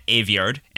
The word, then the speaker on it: Aveyard: I asked you for someone you're jealous of, and you said Victoria Aveyard, who is on Instagram at Victoria Aveyard, and her last name is spelled like graveyard without the G-R, A-V-E-Y-A-R-D.